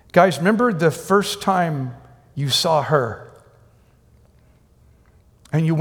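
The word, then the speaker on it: time (τιμή): It's spelled like T-I-M-E in English, but time, and it means to value, to willingly look at something and go, that's amazing, that's beautiful.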